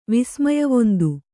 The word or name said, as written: ವಿಸ್ಮಯವೊಂದು